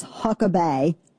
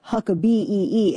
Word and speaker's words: Huckabay: Yeah, so the guy's name is Huckabay. Now, I'm not saying it like Britney Spears. It's spelled like Huck, A-B-A-Y. So not like Huckabee. It's Huckabay, H-U-C-K-A-B-A-Y. See the difference?